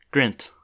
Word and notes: Grint: /ɡrɪnt/; American English

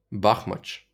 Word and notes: Бахмач: ˈbɑxmɐtʃ

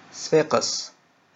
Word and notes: صفاقس: ˈsˤfaːqəs; Tunisian Arabic

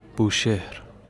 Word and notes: بوشهر: Bushehr; [buːˈʃe(h)ɾ]; Persian

